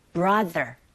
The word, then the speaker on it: brother: 이때 brother 하실 때도 우리말 디귿이 아니라 the, brother 이렇게 입모양 잡아주시고 did라기보다는 did 마치 d 하시고 d 발음을 d 받침으로 보내시면 된다는 거 그렇게 참고하면서 발음해 볼게요.